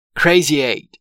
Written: crazy eight

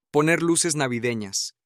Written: poner luces navideñas

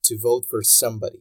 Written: to vote for somebody

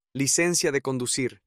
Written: licencia de conducir

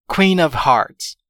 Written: queen of hearts

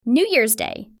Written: New Year's Day

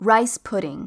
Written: rice pudding

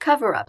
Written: cover up

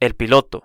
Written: el piloto

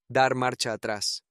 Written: dar marcha atras

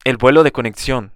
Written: el vuelo de conexion

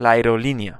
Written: la aerolinea

